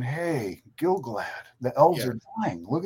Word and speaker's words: Gil-galad: So now we've seen Gil-galad or Gil-galad. I always say it wrong. It's Gil-galad and Celebrimbor be really stupid and really without honor and contrived.